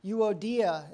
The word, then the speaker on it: Euodia: I plead with Euodia, and I plead with Syntyche.